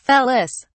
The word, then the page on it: felis: British English